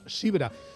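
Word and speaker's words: zebra: Eso es, que en inglés se escribe con Z inicial y se pronuncia Sibra.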